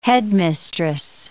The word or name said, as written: headmistress